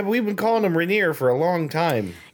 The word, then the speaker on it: Raniere: Do they pronounce it Raniere? – Yes, apparently it's Raniere.